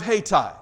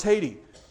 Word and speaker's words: Hayti: Don't know why the GPS took me this way at all, but we got down to the little town of Hayti. Now, at the time, I didn't know how you pronounced it. I thought it was Haiti. And then I was corrected by Missourians who told me, no, that's Hayti.